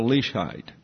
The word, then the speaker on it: Leishheit: And I told you on Sunday that I didn't know how to spell Leishheit. I've learned how to pronounce it. It's leash like a dog, and then height like going up a ladder.